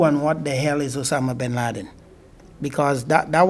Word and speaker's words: Osama bin Laden: Osama bin Laden.